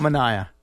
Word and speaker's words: Manaea: Manaea. – Close. Manaea. – I nailed that. – Almost. – Manaea? – Manaea. Yeah. – Like Omar Manaea? – Correct. Pronunciation-wise, yeah.